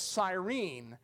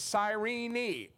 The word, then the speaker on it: Cyrene: And so I had always called it Cyrene, but she said it's Cyrene, the last syllables, but which actually in Greek is probably much closer to how it sounds.